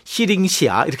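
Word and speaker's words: 시링시아 (西陵峡): 그다음에 서릉협도 시링시아 이렇게 발음을 해야 돼요.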